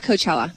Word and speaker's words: Coachella: Coachella has been postponed. – Coachella.